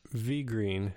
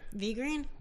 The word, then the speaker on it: VeGreen: V-E-G-R-E-E-N. How would you pronounce that? – Vigreen. – Vigreen? – Yeah.